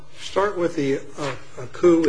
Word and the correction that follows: Akau: is that the correct pronunciation? – Akau, Your Honor.